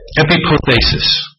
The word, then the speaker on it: epipothesis: This is taken from the Greek word epipothesis. Epipothesis. I'll spell it. E-P-I-P-O-T-H-E-S-I-S.